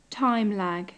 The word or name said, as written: time lag